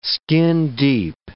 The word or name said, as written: skin-deep